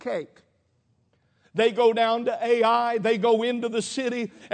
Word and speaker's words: Ai: That's how it's spelled, that's how it's pronounced, Ai.